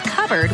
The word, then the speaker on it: cupboard: When we come back, why it's pronounced covered when it's spelled cupboard. What's up with that? – Whew.